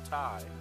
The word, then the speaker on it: Ti: They pronounced T.I. part Thai. I'm dead ass. – Yeah, I'm about to say, is that really how they pronounce it? – Yeah, the 3080 Thai, 3070 Thai.